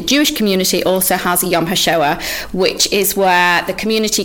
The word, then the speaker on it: Yom HaShoah: You're also part of, I'll let you do to pronounce this properly, in Manchester, Yom HaShoah, yeah, in Manchester.